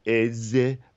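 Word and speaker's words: Èze: I'm sure it's pronounced like Eze, but it's spelled E-Z-E.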